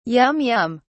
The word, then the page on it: yum yum: British English